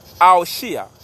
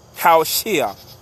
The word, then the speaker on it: Hoshea: Le premier homme nommé ainsi fut Oshia. Oshia s'écrit H-O-S-H-E-A. Qu'ils ont retranscrit par Osée. Ah là là. Quelle catastrophe. En hébreu, Aoshia. H-O-W-S-H-E-A. Haoshia.